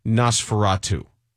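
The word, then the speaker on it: NOS4A2: It's actually NOS, the numeral 4, the letter A, the number 2, pronounced Nosferatu.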